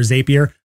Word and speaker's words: Zapier: Z-A-P-I-E-R. People pronounce it Zapier or Zapier. I say Zapier because the things that they have in there, they call their automations, they call them zaps.